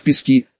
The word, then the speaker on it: Пески: Например, пригород Донецка, поселок Пески, следовало произносить с ударением на первый слог, а не на последний.